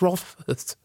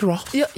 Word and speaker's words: Rothfuss: Rothfuss. – Förlåt? – Patrick Rothfuss. – Jag tyckte det var perfekt. – Så hade han uttalat det själv.